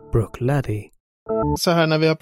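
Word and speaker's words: Bruichladdich: Jag tror att det är så att man även uttalar slutet så att det är Bruchladich. – Vi är hemskt ledsna att bryta programmet för ett klargörande. Här säger David fel. Rätt uttal är Bruchladdy.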